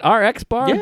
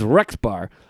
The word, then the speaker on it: RX Bar: The RX bar, is that how you say it? RX bar. – Yeah. – RX bar. I wasn't sure if it was Rex bar.